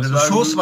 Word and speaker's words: Sousa: Aber das A wurde gar nicht gesprochen. – Genau, Sousa war es eigentlich.